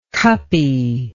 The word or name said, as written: cuppy